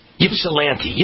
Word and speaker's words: Ypsilanti: Am I pronouncing that right? Ypsilanti? Ypsilanti. Ypsilanti. Okay, Ypsilanti, Ypsilanti.